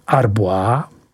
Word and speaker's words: Arbois: Jsou to Arbois, vyslovuj Arbois.